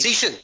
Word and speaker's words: Cixin: I always thought it was Cixin Liu. Right? – Cixin, that's right. That is right. It's like Sersha. So that's my mnemonic device. So yeah, say it again. – Cixin Liu.